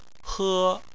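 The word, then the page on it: he: tone 1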